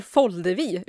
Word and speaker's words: Foldevi: och så har vi Oskar Foldevi, uttalar jag efternamnet rätt? – Ja, det var rätt.